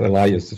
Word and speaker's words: Elias: Norbert Elias, or Elias, if we pronounce it in English,